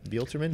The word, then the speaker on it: Buelterman: Buelterman? How do you say that? – Buelterman. – Is that a silent B?